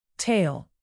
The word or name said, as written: tale